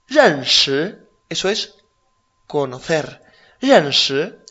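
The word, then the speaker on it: Renshi (认识): Renshi. – Renshi. – Exacto. R-E-N. Ren. Cuarto tono. Shi. – Segundo. – S-H-I. Segundo tono. Renshi. Por ejemplo, wo gei ni jie shao yi ge ni bu renshi de ren.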